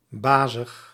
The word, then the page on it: arrogant: France; [a.ʁɔ.ɡɑ̃]